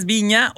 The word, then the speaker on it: Vigna: Y bueno, ya se confirmó, como lo habíamos comentado aquí, es nada más y nada menos que Flor, pues yo digo que le dicen Vigna. – Sí, porque así se escribe, pero o es Viña o Viña.